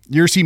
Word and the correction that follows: Ursa: Majors. – Ursa Ursa Ursa Ursa Ursa. Uh, who is um a giant bear in the Winter Guard, which is what the the uh, where the Red Guardian is from.